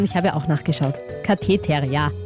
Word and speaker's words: Katheter: Nein, Katheter. – Katheter, okay.